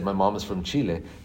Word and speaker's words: Chile: He stopped me, held up his hand, and said, it's pronounced chili. And I thought, okay, but I went on, and the next time again when I said Chile, he once again stopped me. I'll never forget that. Like, he wasn't, I think, trying to be insensitive. He maybe had my best thought in mind, thinking he needs to learn to speak like an English speaker. – Yeah. He just thought you were saying it wrong, which in reality you weren't.